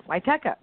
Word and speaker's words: Wietecha: I'm the Marsha, that's the S-H-A, so it's M-A-R-S-H-A. And my last name is spelled W-I-E-T-E-C-H-A. That's pronounced Witeka.